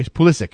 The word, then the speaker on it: Pulisic: He says Pulisic with a hard C at the end. Oh, really? Okay, Pulisic.